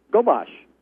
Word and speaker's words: Gobosh: How do you pronounce that, gentlemen? – Is it Gobosh? Just like it looks. The little umlaut above the O threw me off there a little bit.